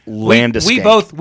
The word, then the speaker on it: Landeskog: Yes, I mispronounced Landeskog.